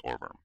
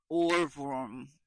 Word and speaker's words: Ohrwurm: Nah, I think it's Orverm. – Spell it. – O-H-R-W-U-R-M. And W's in Germany, you say V, like V, like BMV. – Okay. – Or like Bratwurst. – Orverm.